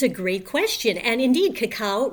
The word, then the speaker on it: cacao: I didn't know how to say it, and so I sort of tried different things out, and you taught me it's cacao. Like the Batman cartoon, but that's not the same as cocoa, the stuff that goes in your hot chocolate drink.